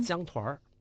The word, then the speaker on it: 江团儿: 他这个当地人发音呢，还带儿化音，就是江团儿，